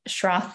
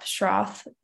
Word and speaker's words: Schroth: I recommend looking up whether or not there are any Schroth therapists in your area. So look up Schroth physical therapy or the Schroth method. That is spelled S-C-H-R-O-T-H.